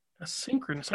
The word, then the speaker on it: asynchronous: So there's still quite a few hanging on, though, but you had something to do with, and I'm probably going to say it wrong, asynchronous learning or asynchronous? – That's pretty close. Asynchronous learning, asynchronous. – Yes. – Okay, asynchronous.